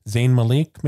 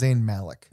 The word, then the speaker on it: Zayn Malik: I'm not a One Direction person, so I don't think I'm saying his name right. Zayn Malik, maybe? – I think it's Zayn Malik. – Zayn Malik.